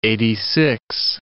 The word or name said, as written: eighty-six